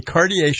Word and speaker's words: Cartier: Did I pronounce it? Cartier. – Cartier, yeah.